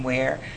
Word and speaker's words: Ware: It's just my name, Janine Ware. – Yeah, that's why. – Twitter is Janine Ware Acts. But Facebook and Instagram, it's just Janine Ware. – Okay, how do you spell it? – It's spelled G-E-N-I-N-E and the last name W-A-R-E.